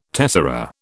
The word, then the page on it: tessera: British English